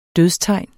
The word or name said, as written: dødstegn